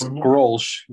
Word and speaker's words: Grolsch: Grolsch. It's a premium pilsner beer, and nobody helps me because it's like fucking. – It's Grolsch.